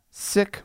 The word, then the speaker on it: Sikh: Where five sick men, that's S-I-K-H. – Yeah. – Often Sikh is how you'll hear that pronounced.